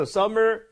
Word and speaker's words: summer: 그러니까 M이 두개 있다라고 해서 우리가 이렇게 발음하는 거가 아니라 그냥 summer에 가깝게 발음해 주시면 됩니다.